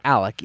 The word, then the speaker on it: Alex: Apparently it's pronounced Alec, even though it spells it Alex.